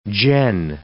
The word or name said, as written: gen